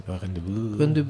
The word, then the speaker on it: Rendezvous: Y es un disco de Cassandra Wilson que se llama Rendezvous, no sé cómo se dice en francés. – Rendezvous.